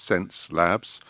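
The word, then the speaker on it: Xence: Okay, so first gadget of the week is from a company called Sense Labs, inexplicably spelt with an X. So it's X-E-N-C-E, Labs, or one word, but pronounced Sense Labs.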